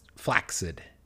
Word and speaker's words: flaccid: But because it's in fact pronounced flaccid. – Oh, I've definitely heard it pronounced that way. But most people say it the wrong way. Flaccid. – Then. Yeah. Flaccid. That makes it sound like almost like you're saying flaxseed.